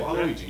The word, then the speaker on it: Waluigi: Waluigi.